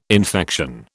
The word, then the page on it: infection: British English